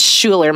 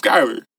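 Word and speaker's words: Schuyler: That's Schuler, my guy. – That's. – Is it? – I pronounce it Skyler.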